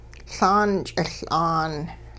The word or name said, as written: Laan ch'iL'aan